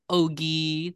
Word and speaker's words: Ogui: My name is Ogie. That's pronounced like Yogi Bear without the Y.